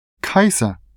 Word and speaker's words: Kaiser: Kaiser. Das schreibt man K-A-I-S-E-R.